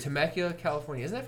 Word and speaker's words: Temecula: Temecula, California, man.